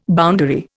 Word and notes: boundary: Indian

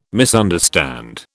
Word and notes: misunderstand: British English

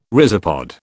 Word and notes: rhizopod: British English

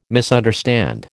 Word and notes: misunderstand: American English

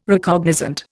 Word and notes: Recognizant: Indian

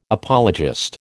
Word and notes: apologist: American English

